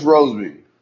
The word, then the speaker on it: Rosemy: So, and then there was that is that Rosemy? Is that how you say it? Rosemy? – Yeah, Marcus Rosemy.